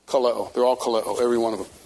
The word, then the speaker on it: kaleo: Kaleo, short E.